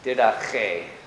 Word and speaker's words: Didache: And so we, it's strictly, the way they would pronounce that in Greek would be like a German CH. Didache.